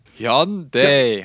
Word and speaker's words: Hyundai: Den, den, den Korean Pronunciation Guide aufgemacht. Hyundai oder so. Hyundai heißt das.